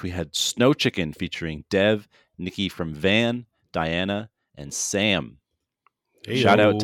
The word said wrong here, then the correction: Diana: I think it's pronounced Deanna.